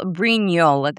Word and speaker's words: Brignol: It's Nicole Brignol. The G is silent.